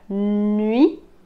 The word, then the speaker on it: nuit: Y la última semivocal, que en el alfabeto fonético es como una H al revés y se pronuncia como ui, ui. Como en el número 8, 8, 8, la palabra nuit, nuit.